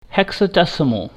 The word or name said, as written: hexadecimal